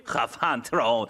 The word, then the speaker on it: خفنتراون: میتونی منو 20 صدا کنی یا بگی خفنتراون. این اسمی که بچه‌ها برام انتخاب کردن. من یه همچین اسمایی برای خودم نمیذارم. در واقع اینطوری تلفظ میشه. خفنتراون.